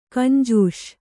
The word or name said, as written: ಗುಂಜಿಸು